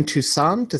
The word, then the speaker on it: Toussaint: L- Lorraine Toussaint, is that how you pronounce her last name? – Played yes.